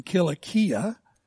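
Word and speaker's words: Kilikia (Κιλικία): What may be more noteworthy is that the capital city of the Roman province of Cilicia, or its proper pronunciation in Greek would have been Kilikia, listed here, happened to be Tarsus, the birthplace and the hometown of Saul.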